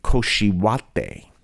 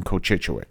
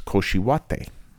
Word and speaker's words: Cochituate: And the Salvadorans taught me to say Cochituate, which is a lot easier to say than Cochituit. And the reason is because that really is probably the proper pronunciation of that word from the Native Americans when they said this is Cochituate.